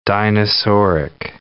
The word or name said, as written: dinosauric